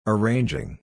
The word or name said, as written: arranging